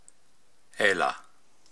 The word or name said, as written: Aila